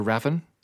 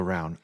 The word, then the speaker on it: Ravn: So I thought it was Olga Raven, but I'm here to tell you it's Olga Raun, R-A-V-N. Danish name, very difficult to pronounce.